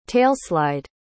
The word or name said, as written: tailslide